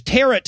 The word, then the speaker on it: tarot: witches use tarot cards. – Tarot, which rhymes with carrot. Not a lot of people yes. – knew that tarot cards rhymed with carrot cards.